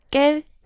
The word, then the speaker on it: quel: 그래서 발음은 다 똑같아요. 깰, 깰, 깰, 깰.